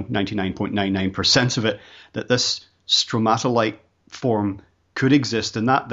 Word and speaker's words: stromatolite: And one of the most intriguing things for me was, I hope I'm pronouncing this correctly, but it was a very early development in the Earth. Was it stromatolite? Am I saying that correctly? – Yes.